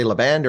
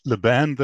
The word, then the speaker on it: LaBand: Is it LaBond or do you say LaBand or how do you say it? – I said LaBand, though it's really a German name, so it's LaBand, I suppose.